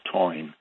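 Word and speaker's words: Táin: It's spelled T-A-I-N, but it's pronounced Toine, and which means cattle raid in Irish.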